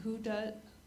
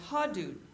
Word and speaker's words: Hudud: And there's something called, I don't know how you say it, H-U-D-U-D, Hudud, Hudud? – H-U-D-U-D. – Yeah. – Hudud. – Hudud. – Okay. – And, oh, Hadud. Well, you spell it different ways.